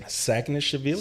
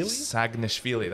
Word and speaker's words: Saginashvili: I'm not even going to try to pronounce his second name. – Saginashvili. Saginashvili. – That's Saginashvili. Yeah. I've been I've been actually hoping that this one would happen.